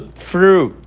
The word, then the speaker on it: through: A pronúncia do through dá um pouco mais de trabalho do que os anteriores, porque para você pronunciar o TH, você coloca a sua língua entre os dentes e deixa o ar sair de uma maneira mais leve. Through, through.